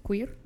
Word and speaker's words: queer: Sí, porque queer, no tiene U. – Pues sí tiene, ¿no? – Queer, ah, sí, Q-U. – Sí, es Q-U-E-E-R.